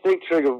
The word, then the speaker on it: Sigtryggr: So although Uhtred wields considerable power in Northumbria, the kingship actually belongs to his son-in-law, Sigtryggr. – Yes. – Am I saying that correctly? Tell us about him. – Well, yes, Sigtryggr was, this is where, there's always sort of conflict when you're writing historical novels, because history sometimes doesn't give you what you want.